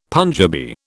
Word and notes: Punjabi: British English

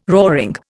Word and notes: roaring: Indian

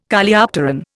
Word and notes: Coleopteron: Indian

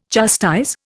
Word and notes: chastise: Indian

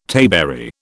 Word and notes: tayberry: British English

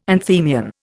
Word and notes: anthemion: Indian

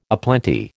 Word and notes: aplenty: American English

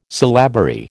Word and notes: syllabary: American English

